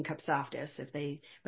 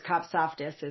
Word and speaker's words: Kopsaftis: But of course, Kopsaftis is kind of a handle. My husband jokes and says, it's only 3 syllables and it's very phonetic. I go, yeah, but it's 9 letters and only 3 vowels.